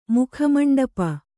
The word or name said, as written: ಮುಖಮಂಡಪ